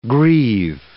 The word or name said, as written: grieve